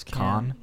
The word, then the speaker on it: Caan: How do you pronounce that? – I think it's Khan.